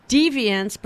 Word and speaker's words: Dviance: Deviance, before that, that's spelled D-V-I-A-N-C-E, and Lauren Otter together with Cry.